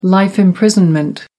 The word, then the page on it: life imprisonment: American English